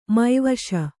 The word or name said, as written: ಮಾವಶೀ